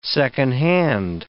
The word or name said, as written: second hand